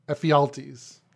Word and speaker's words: Ephialtes: Well, not only that, but how did the mutant dude, what is it, Epiphiles? Is that how his name, you pronounce his name? – That would be, sorry, I forgot his name. Ephialtes. – Oh, Ephialtes. Okay, yeah, thank you.